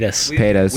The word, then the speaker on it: Paytas: Paytas.